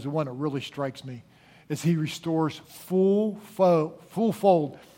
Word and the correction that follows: fourfold: What did I say? He restores fourfold. Did I say it right that time?